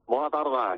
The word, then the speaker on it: Bona tarda: Bona tarda, que diria el Petón. – Bona tarda. – Pero bien pronunciado, ¿no? ¿O no? – Perfectamente.